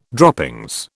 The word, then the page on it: droppings: British English